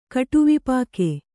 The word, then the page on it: ಕಟುವಿಪಾಕೆ: kaṭuvipāke